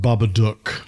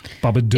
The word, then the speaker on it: Babadook: By the way, not to be that guy, but it is pronounced Babadook. – Babadook. – It is? – Because it's supposed to rhyme with book.